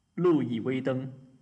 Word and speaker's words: Louis Vuitton: be okay we'll start with the easy one okay Louis Vuitton.